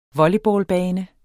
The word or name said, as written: volleyballbane